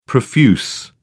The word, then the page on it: profuse: British English